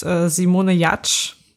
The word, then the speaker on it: Simone Giertz: Okay, meine Person der Woche ist Simone Jatsch. Sie wird zwar so ausgesprochen, aber sie wird anders geschrieben. Sie wird Simone Giertz geschrieben. Und sie ist wie? Hast du gerade wie gesagt? – Ja. – Simone Giertz. G-I-E-R-T-Z.